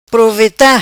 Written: Proveta

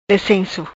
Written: Lecenco

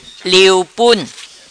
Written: Liu Pun

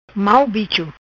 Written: Bicho-Mau

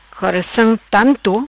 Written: Coraçam Tanto

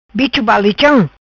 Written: Balicham bicho

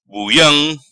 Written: Buiam

